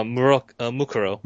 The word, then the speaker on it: Mukuro: What if this is actually Muro? Muro? How do you say her name? – Well, Mukuro.